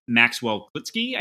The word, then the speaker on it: Maxwell Klitzke: but it's at Maxwell Klitsky, I think is, I hope I'm pronouncing it correctly. – That's right. – M-A-X-W-E-L-L-K-L-I-T-Z-K-E.